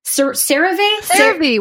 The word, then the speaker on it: CeraVe: CeraVe.